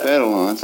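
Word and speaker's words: fer-de-lance: Fair de lance.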